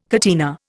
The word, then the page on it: catena: Indian